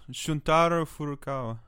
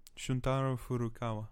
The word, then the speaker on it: Shuntaro Furukawa: Shuntaro Furukawa. Yeah. Shuntaro Furukawa. – You can't tell we're from South Australia. – Shuntaro Furukawa.